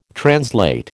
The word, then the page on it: translate: American English